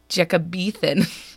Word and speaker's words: Jacobethan: Anywho, it is pronounced Jacobethan.